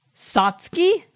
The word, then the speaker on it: Satsuki: Yeah, this is like Kill a Kill if both characters were Sasuke. – Satsuki. – How wrong was that pronunciation? – You named.